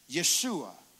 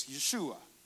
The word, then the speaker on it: Yeshua: Joshua, we said that Joshua, if you say his name in the Hebrew language, it is pronounced Yeshua, which is the name of Jesus, Yeshua.